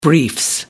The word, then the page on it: briefs: British English